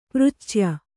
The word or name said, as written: ಪೃಚ್ಛ್ಯ